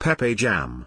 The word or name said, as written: pepeJAM